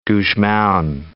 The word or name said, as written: Gusmao